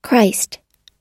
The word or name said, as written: christ